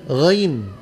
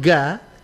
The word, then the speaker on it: ghain: Ya, huruf ghain. Jangan bilang ga, karena itu cara bacanya masih lurus, lidahnya lurus. Kalau dinaikkan pangkal lidah, bukan lagi ga yang kedengaran, melainkan r, r, r. Tinggal naik pangkal lidah.